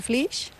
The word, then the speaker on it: Flix: Es un pueblo de Tarragona, lo que pasa es que se escribe F-L-I-X. – Pero o sea, no es el Flis que estamos viendo aquí. – No, en catalán se pronuncia Flis.